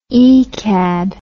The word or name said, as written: ecad